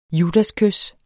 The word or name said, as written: judaskys